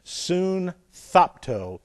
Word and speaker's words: sunthapto: This, you have, it looks like, I just want to show you one, S-U-N-T-H-A-P-T-O. This is soon, S-U-N-T-H-A-P-T, and long O on the end. Soon thopto. Soon thopto. In the Greek, whenever you have an S-U-N prefix on words, this is together.